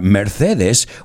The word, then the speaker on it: Mercedes: And when I say the word Mercedes, or bueno, lo pronuncio a la española, Mercedes, what do you think of?